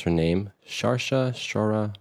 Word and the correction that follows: Saoirse: Ronan. Guys, Saoirse.